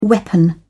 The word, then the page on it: weapon: British English